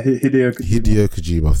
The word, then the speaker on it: Hideo Kojima: Is it Hadjioe Kajima? – Yeah, Hideo Kojima.